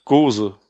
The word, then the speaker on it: Gose: Ein Alemannia Craft Beer Goose Mania. – Oder auch Gose. – Gose? Ja. Ich weiß nicht genau, wie das ausgesprochen wird,